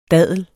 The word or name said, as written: daddel